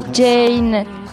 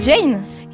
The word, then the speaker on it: Jane: C'est la mauvaise prononciation de Jeanne. – Jane. Euh, c'est Jane Ok, Jane.